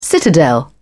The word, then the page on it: citadel: British English